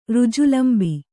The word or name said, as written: ಋಜುಲಂಬಿ